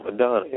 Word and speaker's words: Maidana: Maidana, yeah, I pronounced his name right.